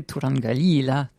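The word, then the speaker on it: Turangalîla: Donc c'est Tourangalila 3.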